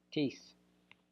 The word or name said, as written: tis